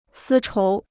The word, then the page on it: 丝绸: sī chóu